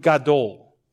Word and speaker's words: gadol: And the word for great here in the Hebrew is the word gadol, okay? Gadol, G-A-D-O-L, gadol. It's actually not that in Hebrew, but I can't pronounce those letters, all right?